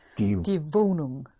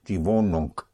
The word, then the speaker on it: Wohnung: So let's go back to the apartment, which is die Wohnung. – In German, the NG, the G in NG is pronounced. Yes? Die Wohnung.